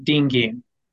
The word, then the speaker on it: Dean Giem: Did I pronounce that right? – You can say it however you want to. It gets butchered all the time. But ultimately, if you just say them together, you can't forget it. Dean Giem.